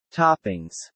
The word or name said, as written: toppings